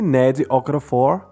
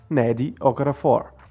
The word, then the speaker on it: Nnedi Okorafor: Binti, de Ned Okorafor. Bint se escreve B-I-N-T-I e Ned Okafor se escreve N-N-E-D-I O-K-O-R-A-F-O-R. Ned Okafor.